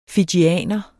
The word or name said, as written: fijianer